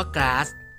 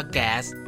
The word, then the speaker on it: gas: คำว่าก๊าซกับแก๊สทับศัพท์มาจากภาษาอังกฤษคำเดียวกันคือคำที่เขียนว่า GAS แต่ทับศัพท์การออกเสียงที่แตกต่างกันถ้าเป็นภาษาอังกฤษสำเนียงอเมริกันจะออกเสียงว่าแก๊สแต่ถ้าเป็นภาษาอังกฤษสำเนียงอังกฤษจะออกเสียงว่าก๊าซจ้ะ